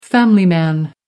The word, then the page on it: family man: American English